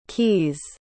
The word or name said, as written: caius